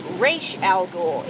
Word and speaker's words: Ra's al Ghul: And it does teach you that you say it as Ra's al Ghul. It is not Ra's, it's Ra's. That's how it's pronounced.